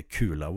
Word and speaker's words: Kula: Men det som hade passat bättre och som hade givit så jäkla mycket mer underhållning, det tycker jag är Kula World. Och ja, jag uttalar kula väldigt svenskt. För det stavas med K-U-L-A.